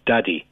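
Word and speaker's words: Daði: The guy singing for Iceland, I think his name is pronounced Daddy. That's up for debate.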